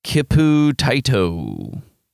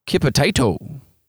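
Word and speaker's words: Kiputyttö: Their name is spelled K-I-P-U-T-Y-T-T-O. Kipu Taito. – That sounds Finnish. – Does it? – Sounds Nordic as fuck. – Kipu Taito.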